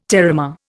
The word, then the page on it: terramare: Indian